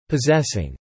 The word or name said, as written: possessing